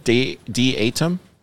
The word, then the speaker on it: Datum: Is it Datum? Datum? Dudum? – I would pronounce it Datum.